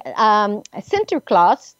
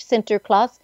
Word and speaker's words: Sinterklaas: And I know when I lived in the Netherlands for about 18 months, and Sinterklaas, that's how you say it in Dutch, Sinterklaas, December 6th, was a bigger celebration day than than Christmas Day, and it was something that we really looked forward to.